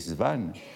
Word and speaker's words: Swann: Et vous savez que dans le roman, euh, il y a au moins deux occurrences où Swann est prononcé Svan euh, par euh, Norpois.